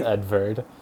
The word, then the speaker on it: Edvard: I would assume it's German, and if it's a V, it's almost definitely pronounced Edvard.